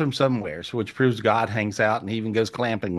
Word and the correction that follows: glamping: Did I say it right? Clamping? No, not clamping. Glamping, and eat with me, camping, and so.